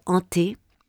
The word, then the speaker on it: Anthe: Ante. – Yeah, it's spelled like Anth. A-N-T-H-E.